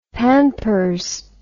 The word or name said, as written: pampers